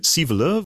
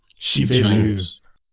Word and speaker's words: Sivelöv: Is it Sivelöv? – Yeah, in Swedish we say Sivelöv. But I'm used to having Sivelöv or something like this in outside Sweden.